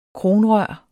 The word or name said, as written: kronrør